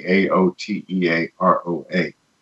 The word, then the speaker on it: Aotearoa: And particularly um, the the word that I somewhat struggled with is the Maori name for New Zealand, and it's Aotearoa. It's spelled in a, a weird way, A O T E A R O A but pronounced as I've come to learn Aotearoa.